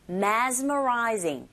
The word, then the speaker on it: mesmerizing: 특히 mess라기보단 z로 발음해 주셔야 돼요. mesmerizing.